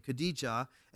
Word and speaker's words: Khadija: His first wife was Khadija, is how they pronounce her name, Khadija.